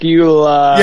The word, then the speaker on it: Bakula: Bakula.